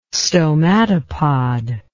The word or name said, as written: stomatopod